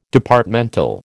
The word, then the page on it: Departmental: American English